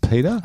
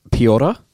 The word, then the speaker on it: Piotr: Is it pronounced Peter? – I think it's Piotr. – Piotr Yarn. – Piotr Yarn. Yeah. – I think you're right there.